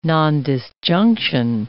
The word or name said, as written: nondisjunction